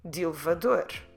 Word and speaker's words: de elevador: We know it's two words and when we write them down, it's clearly two words, de elevador, but elevador starts with a vowel and when we say de, we're dropping the vowel sound. So when we pronounce it, we tend to put them together.